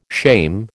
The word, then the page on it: shame: American English